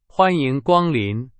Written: 光临

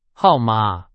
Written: 号码

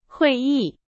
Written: 会议

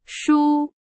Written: 输

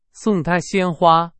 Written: 鲜花

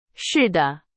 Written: 似的

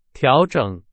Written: 调整

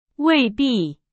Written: 未必

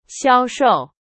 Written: 销售

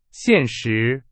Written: 现实

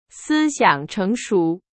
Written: 成熟

